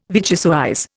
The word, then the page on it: vichyssoise: Indian